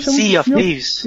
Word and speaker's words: Sea of Thieves: no próximo jogo, cara, depois do Cuphead, esse jogo tá dois, três esperando no Vinícius, é o Seal of Thieves, né? Como é que pronuncia? – Sea of Thieves. Eu chamo de Sea of Thieves, porque os piratas são lá, velho.